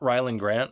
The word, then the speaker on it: Rylend Grant: I am at Rylend Grant on all forms of social media.